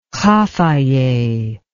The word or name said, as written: Khafaje